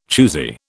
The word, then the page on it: choosy: British English